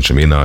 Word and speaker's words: Chimena: Und ein Titel davon, nämlich Chimena, kommt in die, so spricht man Chimena, ein Name nur Spanisch gesprochen, wie du sagtest, als eine Neuvorstellung auf 8 Wochen in die Hitparade.